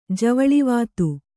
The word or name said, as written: ಜವಳಿವಾತು